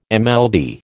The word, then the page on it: MLD: American English